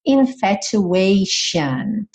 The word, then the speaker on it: infatuation: Infatuation. Então, lembra que esse sufixo T-I-O-N sempre vai ser pronunciado shan. Shan. Uhum. E aqui, como a gente tem esse A antes, a gente vai ter esse ation. Uhum.